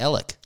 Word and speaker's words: Alex: And you know, there's a town called, it's spelled Alex, A-L-E-X. There's a town in Oklahoma called that. And everyone around there calls it Alec.